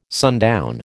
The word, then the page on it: sundown: American English